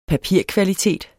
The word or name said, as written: papirkvalitet